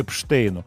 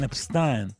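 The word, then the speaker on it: Epstein: Сегодняшнее посвящено Брайану Эпштейну, мне менеджеру Битлз. Вот. Э, тут же мне стали звонить битломаны, но «Ну, почему-то написал Эпштейн, а не Эпстайн. Ведь правильный произносится его имя Брайан Эпстайн.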